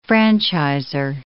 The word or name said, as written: franchiser